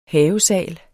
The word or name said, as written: havesal